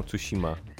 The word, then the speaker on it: Tsushima: Wymawiają SH jako EŚ, na przykład jak masz Tsushima, to wymawiają Tsushima.